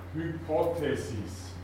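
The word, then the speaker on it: Hypothesis (ὑπόθεσις): Betonung über den Omikron. Hypothesis. Hypothesis, Hypo heißt unter, darunter.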